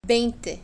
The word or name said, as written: veinte